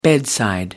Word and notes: bedside: American English

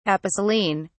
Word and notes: aposelene: British English

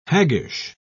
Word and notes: haggish: British English

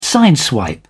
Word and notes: sideswipe: British English

